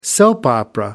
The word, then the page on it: soap opera: American English